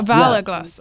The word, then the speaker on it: Valor Glass: Valor Glass, okay.